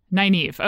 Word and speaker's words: Nynaeve: How do you even say her name? – Nynaeve. – Okay. I always say Nineveh because it sounds like King Arthur to me.